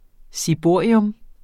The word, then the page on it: ciborium: siˈboˀɐ̯iɔm